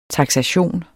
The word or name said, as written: taksation